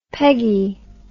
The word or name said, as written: Peguy